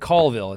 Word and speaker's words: Colville: And yeah, we call it Colville. It's pronounced Colville.